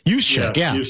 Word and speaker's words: Juszczyk: fullback Kyle Juszczyk. I never pronounce his name correctly. – Juszczyk.